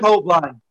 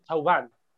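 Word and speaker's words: Thauvin: Que tiene la pronunciación correcta, Taubán.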